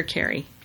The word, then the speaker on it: Kari: Is it Kari or Carrie? – K-A-R-I? – Yeah. Sometimes it's Kari. Yeah. – Carrie? – Sometimes it's Carrie. I don't know. Some people pronounce their names different every time.